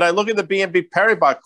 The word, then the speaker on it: Paribas: It's worth noting, Dan, that BNP Paribas, I just like saying that because, you know, the S is silent, as you know, because you work for one of those fancy places.